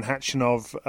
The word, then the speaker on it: Khachanov: Catherine, first of all, we're going to hear, as I said, from Andy Murray talking to you after his victory over Karen Khachanov a little bit later in this show.